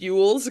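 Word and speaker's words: Elwes: Am I being made to understand that his last name is pronounced Yules? Because I had no idea. – I had no idea how to pronounce it. – Yeah. – I believe it's Carrie Yules. – I thought it was L-O-S, but I just keep being phonetic.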